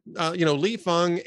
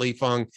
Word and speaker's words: Fang: That is literally and definitionally fascism, especially when all of the issues that, Li Feng and I believe he pronounces it, it's spelt Fang, but I think he pronounces it Li Feng and Ken Klippenstein note.